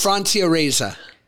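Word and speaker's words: Francia Raisa: How I Met Your Father, Leighton Meester, Hilary Duff, and Francia Reyes. Am I saying that right? Francia Reyes? – Francia Reza.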